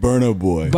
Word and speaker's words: Burna Boy: Burna Boy. Is that how you say it? – Burna Boy. – Burna? – Yeah. – That's not how the guy told me to say it. – That's how it reads.